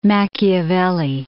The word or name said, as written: Machiavelli